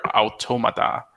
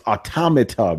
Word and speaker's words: Automata: So other than Heroes of the Storm, I've been playing Nier Automata. – Yeah. – So man. – I love all the different pronunciations. It's automata, man. It's a play on the word automaton.